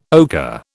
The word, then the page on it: ochre: British English